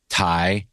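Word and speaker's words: Tighe: Chris Cooper, before he was super famous, James Earl Jones, Mary McDonnell, Kevin Tai, is that how you say his name? From Emergency? – Yeah.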